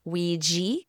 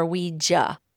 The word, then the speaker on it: Ouija: It's either Ouija or Ouija. I like to say Ouija just because that's what I'm familiar with. But it can be pronounced either way.